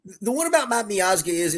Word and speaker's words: Miazga: I assume it's Miazga. – Yes, that's correct.